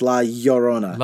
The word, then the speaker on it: La Llorona: Yeah, it's LL, so it's La Llorona.